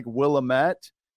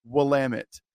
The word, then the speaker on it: Willamette: You're like, that's how you pronounce it? It looks like Willamette. It's pronounced Willamette. Just like Oregon is pronounced Oregon and not Oregon.